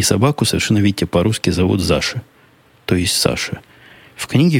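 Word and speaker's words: Zasha: Заша так и пишется, как слышится, через Z. Z-A-S-H-A. Заша. Ну, как Саша, только почему-то с Z.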